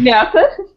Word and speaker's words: Миасса: Житель Миасса... Не слово «мясо». Я нормально произношу. – Челябинская область, Миасс, мы знаем, ну. – Да, Миасса. Я говорю, как будто мясо, блин, что за хер? – Житель Миасса.